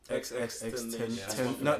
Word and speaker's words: XXXTentacion: Yeah, how do you pronounce his name? – XXXTentacion. – Oh, he's right, you know.